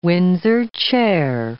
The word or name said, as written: Windsor chair